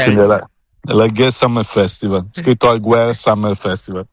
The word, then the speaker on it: Alguer: Alguer senza la si pronuncia senza.